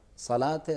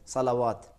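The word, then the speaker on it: صلوٰۃ: جیسے صلات ہے, زکوٰۃ ہے, بہت سے لوگ یہی کہتے ہیں جب دیکھ رہے ہیں الف ہے تو فوراً کہتے ہیں صلوات, زکوات. جیسے صلات ہے صلوات پڑھتے ہیں, حالانکہ اس میں اور اس میں زمین و آسمان کا فرق ہے.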